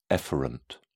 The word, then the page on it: efferent: British English